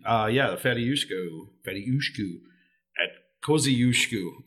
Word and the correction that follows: Kosciuszko: I think I'm pronouncing it. Where did they even get that name? – It's a Polish general thing. – Kosciuszko. – There you go.